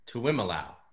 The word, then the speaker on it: Tuiamoloa: Tuiamoloa.